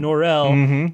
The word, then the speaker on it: Norrell: The first time I nominated him was because he got Mr. Norrell to pronounce his name Norrell the French way, the way he's pronouncing it.